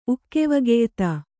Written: ಉಕ್ಕೆವಗೆಯ್ತ

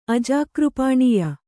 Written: ಅಜಾಕೃಪಾಣೀಯ

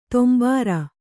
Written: ತೊಂಭಾರ

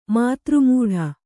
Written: ಮಾತೃಮೂಢ

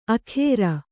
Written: ಅಖೇರ

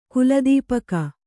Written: ಕುಲದೀಪಕ

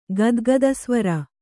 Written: ಗದ್ಗದಸ್ವರ

